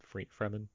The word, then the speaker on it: Fremen: I can't even tell you what the Mentat, that Thurfur Howe, the Fremen, the Fremen, that's just free man combined and like pronounced slightly differently.